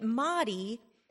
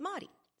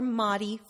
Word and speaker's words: Mari: It's not Marisol, in fact, and they pronounce the shortened form of it, Mari, as if it rhymes with Atari or Calamari, when in fact, it's not Mari, it's Mari. So for the record, my name is Marisol, or Mari for short. It rhymes with body, soul, think holistic.